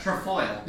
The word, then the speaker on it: Trefoil: Trefoil.